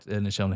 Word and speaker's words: Nichelle: Because I always used to think I was saying the name wrong because I was like, obviously someone's typed it wrong. Michelle, yeah. It's Michelle. But it's not. Turns out I'm just an idiot.